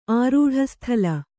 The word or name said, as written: ಆರೂಢಸ್ಥಲ